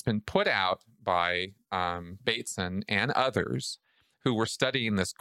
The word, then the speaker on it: Bateson: I kept calling him Batson. Okay, Gregory Bateson.